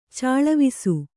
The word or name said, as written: ಚೆಲ್ವೆಸೆ